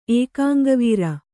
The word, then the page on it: ಏಕಾಂಗವೀರ: kannada